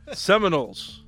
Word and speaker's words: Seminoles: You keep saying it wrong, too. – Seminoles. – There you go, fella.